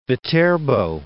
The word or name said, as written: Viterbo